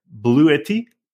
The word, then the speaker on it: Bluetti: This week, the Electric Podcast is sponsored by Bluetti.